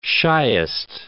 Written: shyest

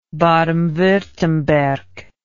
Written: baden wurttemberg

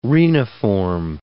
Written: reniform

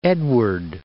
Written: Edward, Lake

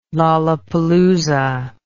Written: lollapalooza

